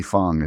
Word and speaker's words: Fang: And again, Lee Fong, it's F-A-N-G. I think it's Fang, but it's pronounced Fong.